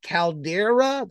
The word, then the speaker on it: caldera: Okay, am I pronouncing this right? Caldera, the Dreaming Caldera. – Yeah, that's right.